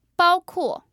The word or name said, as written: baokuo